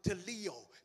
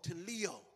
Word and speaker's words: teleo: That word there in the Greek literally is the word teleo, T-E-L-O, with a long O, teleo.